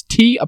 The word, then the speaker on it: T'Telir: I think it's Tetelier. – Tetelier. – You just skip the T? – Well, yeah, they're silent, obviously. – It's for anyone at home, it's T'Telir.